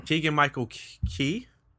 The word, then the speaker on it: Keegan-Michael Key: Keegan-Michael Key. Did I say that right? – Yeah, you said it right.